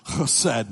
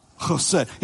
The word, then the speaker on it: Chesed: You can say it with me. Chesed. I'll try it again. Chesed. Yeah, if you spit on the person sitting in front of you, you said it correctly.